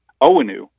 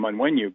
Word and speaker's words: Onwenu: Mike, we all call him Onwenu, but he explained that his name's pronounced differently than that. The first N is silent per the way they say it, I believe Nigeria.